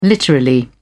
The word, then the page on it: literally: British English